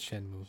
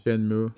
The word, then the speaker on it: Shenmue: It's Shenmue. – Shenmue.